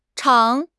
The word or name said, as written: zhang3